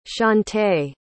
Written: chaunte

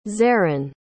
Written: zerrin

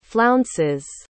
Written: flounces

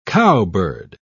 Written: cowbird

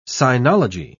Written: sinology

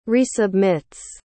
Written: resubmits

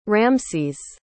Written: ramses

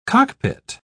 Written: cockpit

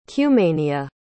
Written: cumania

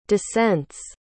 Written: descent's

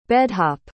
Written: bed-hop

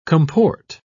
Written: comport

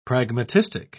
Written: pragmatistic

